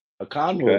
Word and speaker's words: Okongwu: Onyeki Okongwu? – Yes. Okongwu, okay.